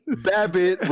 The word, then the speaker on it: Babbitt: Oh, because like David said, Babbitt rhymes with rabbit, Easter bunny, boom, conspiracy closed.